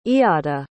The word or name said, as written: eada